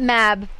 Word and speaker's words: Mab: And is it Mob? Am I pronouncing it correctly? – Mab. – Mab, thank you.